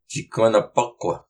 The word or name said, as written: Ciqunapuq